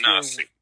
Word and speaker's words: Tanasi: Yes, because along the banks of the river, where Knoxville is today, was a Cherokee village called Tanassee. And that's Tanassee. – Tanassee. T-A-N-A-S-I is how we transliterate it, but Tanassee is where we get the name Tennessee, and it was from that little village.